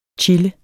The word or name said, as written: chille